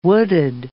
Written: wooded

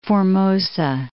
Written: Formosa

